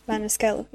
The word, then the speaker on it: Maniscalco: Yeah, is it Maniscalco? Is that how you pronounce it? Yeah, Maniscalco, yeah.